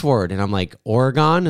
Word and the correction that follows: Oregon: And they're like, no, it's Oregon.